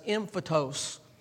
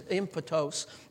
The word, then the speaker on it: emphutos: For the word implanted or planted right there, the Greek word is emphytos, okay? It's spelled E-M-P-H-U-T-O-S, and that's how it transliterated into English. It's emphytos.